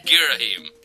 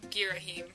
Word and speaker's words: Girahim: ...comes from Casato, and they say, what is the correct pronunciation of Girahim? – Well, the general... – There you go. Girahim. – The general consensus is Girahim.